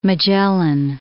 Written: Magellan

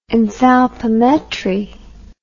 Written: enthalpimetric

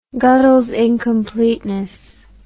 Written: godels incompleteness theorem

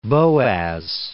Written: Boas

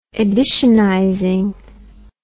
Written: editionizing

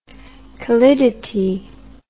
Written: callidity

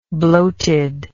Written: bloated

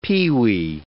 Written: pewee

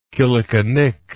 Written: killickinnic

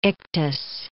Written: ictus

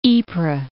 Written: Ypres

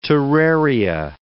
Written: terraria

